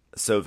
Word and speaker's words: Solvang: How do you pronounce it? – Solvang. – That's a real way to pronounce it. Solvang! – It has a G at the end of it.